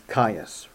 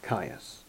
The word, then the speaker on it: Gaius: Is that pronounced Caius? – Caius, right. Caius. Okay, who was the fifth emperor? – Claudius.